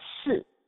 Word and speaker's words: si: Also, the number four is a very unlucky number in the Chinese culture, because the number four is pronounced as si. And that also has a similar sound for another Chinese word that means dead or death.